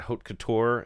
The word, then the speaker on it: haute couture: Haute couture, is that the right...